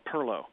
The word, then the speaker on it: pilau: pirlo. Now, it's spelled P-I-L-A-U, pilau, but if you pronounce it that way, you will self-identify as a Yankee rather quickly down there.